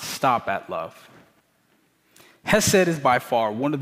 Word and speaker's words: chesed: So one of the Hebrew words for love is hesed, or should be pronounced chesed, which is actually a difficult word to translate in English, thus the many translations.